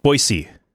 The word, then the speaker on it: Boise: Boise, is that how they say it? – Boise. There is no Z, it's an S.